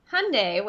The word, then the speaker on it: Hyundai: So the Hyundai, which rhymes with Sunday, Kona, is a new small sport utility vehicle.